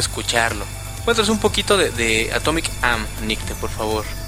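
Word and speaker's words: Atomique Âme: De Atomic Ame. – Atomic Am. – Atomic Am. Disculpen la mala pronunciación.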